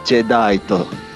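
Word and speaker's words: Jadeite: No caso, o primeiro vilão, que é o, o Jedit, ou a pronúncia em japonês, alguém, por favor? Jedaito. – É, exatamente.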